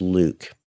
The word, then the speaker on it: LIWC: L-I-W-C. – Linguistic inquiry and word count. – Which I pronounce Luke.